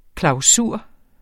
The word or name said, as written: klausur